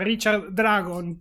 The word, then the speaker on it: Richard Dragon: Richard Dragón. ¿Lo dije bien? – Yo el acento normalmente lo pienso en la A, o sea, Richard Dragón, pero bueno, no nos va a estar escuchando, y si nos escuchase le pediríamos disculpa.